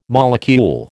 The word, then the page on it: molecule: American English